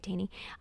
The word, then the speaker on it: Taney: Oh, I'm saying that right, Taney.